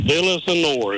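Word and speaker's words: Villaseñor: This is Jacqueline. Villasenor is how you say it. I can't say it fast, but I've watched them play her or sister play for a long time.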